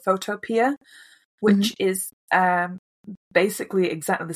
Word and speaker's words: Photopea: Photo and then P-I-A, Photopea, that's how I pronounce it. – I think it's P-E-A. – P-E-A, Photopea.